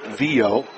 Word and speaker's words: Viau: Spelled V-I-A-U. Sophomore second baseman. Pronounced Vio.